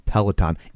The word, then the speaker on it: Peloton: So, okay, we know, no need to write in, we know that it's pronounced Peloton.